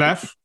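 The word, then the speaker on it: Leffe: So Labatt, which is part of Budweiser, Corona, Stella Artois, Beck's, Leff. Am I pronouncing that right? – Leff.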